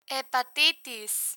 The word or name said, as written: hepatitis